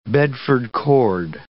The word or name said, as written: Bedford cord